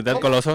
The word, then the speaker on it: Dead Coloso: Sí, Dead Coloso. – Dead ah, Coloso, sí. claro. supiste pronunciar Dead Coloso. Como debe ser, Y así con el tono.